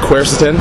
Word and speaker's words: quercetin: Now, the active ingredient is, looks like it's, how do you pronounce that, quercetin? – Yeah, it's pronounced quercetin.